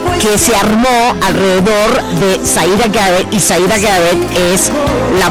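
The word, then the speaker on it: Siedah Garrett: Así es, y bueno, ya extrañaba la pronunciación perfecta que tienes, porque así es Zaira Garrett, tienes toda la razón.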